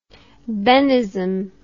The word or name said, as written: Bennism